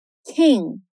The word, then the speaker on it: king: Então perceba: king, Olha como fecha ali o som do G, fica bem sutil, sem nenhuma vogal depois.